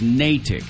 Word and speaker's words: Natick: Hey, we got an email from somebody from who lives up there that says it's Natick. – Natick. All right. I apologize. – No, you didn't know. – It's spelled N-A-T-I-C-K, so I thought it was Natick. You know, and you all said Christmas attics up there, so I was thinking attic.